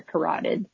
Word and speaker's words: carotid: That, I took anatomy and physiology, so I think you're right there, carotid.